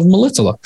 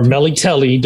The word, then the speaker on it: Melitele: G-U-L. Golden. Yeah. – Yeah. And his primary religion was worship of Melitela, or Melitele, depending on how you want to pronounce it.